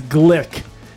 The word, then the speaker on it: Gluek: We were trying to figure out how to say it, but it is Glick. It is Glick, and we're going to sip it up right now.